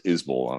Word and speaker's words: Isbell: I said, you know, there's some question about how Isbell is pronounced. And Isbell says Isbell. And I said, hey, man, how do you pronounce the name on your truck? And he said, Isabel. I said, well, wow, okay. Because there's this famous musician named Jason Isbell. And he says, Isbell, just curious.